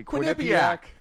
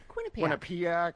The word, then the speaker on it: Quinnipiac: Quinnipiac. – Quinnipiac.